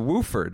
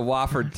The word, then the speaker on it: Wofford: And you pronounce them the Woford or the Wooford, Wooford Terrapins, otherwise also known as the Wofford Terriers, not the Wooford Terrapins.